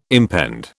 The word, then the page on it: impend: British English